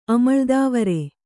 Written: ಅಮಳ್ದೇವರ್

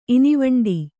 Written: ಇನಿವಂಡೀ

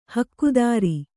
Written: ಹಕ್ಕುದಾರಿ

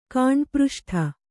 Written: ಕಾಂಡಪೃಷ್ಠ

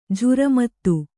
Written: ಝುರಮತ್ತು

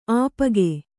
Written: ಅಪ್ಪುಗೈ

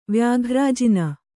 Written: ವ್ಯಾಘ್ರಾಜಿನ

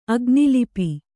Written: ಅಗ್ನಿಲಿಪಿ

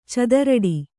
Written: ಚಿತ್ತರಟೆ

